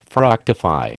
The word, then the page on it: fructify: American English